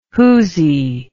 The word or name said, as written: whoosy